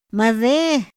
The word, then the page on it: ma de: Otomí